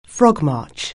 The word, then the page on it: frogmarch: anglais